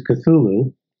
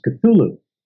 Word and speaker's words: Cthulhu: And I mentioned Cthulhu, and he said, No, no, that's wrong. That's not how I said it. I don't remember how I said it. And he said it correctly for me. He said, It's pronounced Cthulhu. So I was, you know, young and cocky and stupid. And I said, Well, how do you know that's how it's pronounced? And he said, I asked Lovecraft.